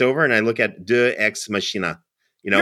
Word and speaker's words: deus ex machina: So the Eye of Thundera was directly activated and came to life in many episodes of the Thundercats and was their deus ex machina.